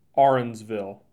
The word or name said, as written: Arenzville